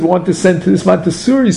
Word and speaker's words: Montessori: What's it called? Mansuri? I'm not sure if I'm pronouncing Montessori. – It right. Montessori.